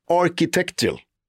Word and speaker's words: architectural: Architectural. Digest. Digest. Det är det jag försöker säga, architectural. det är det är, det är liksom man ska uttala K där i mitten.